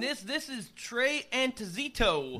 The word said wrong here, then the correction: Anastasio: Nah, that'd be Anastasio. – Anastasio, yeah, I said it right before.